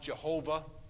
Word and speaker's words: Yahweh: Capital L-O-R-D in the Old Testament English Bible. It's Yahweh, sometimes pronounced Jehovah, but Yahweh is probably closer to the way they said it.